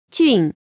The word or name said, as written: jun4